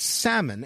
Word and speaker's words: salmon: "The word salmon, as Larry puts it, like a number of other words, have a silent L. Would, could, should, talk, walk, half, calf, caulk, soldier." Soldier, the L is not silent.